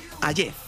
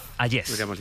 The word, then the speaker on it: Hayez: Hayez. – Hayez.